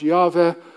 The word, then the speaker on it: Yahweh: And all of these, and there are 40, 50, 60 of them in the Bible, all these are telling you what Yahweh, Jehovah, better pronounced Yahweh, does for you and for me, his salvation.